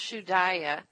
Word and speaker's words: shudaya: It's pronounced shudaya, S-H-U-D-A-Y-A.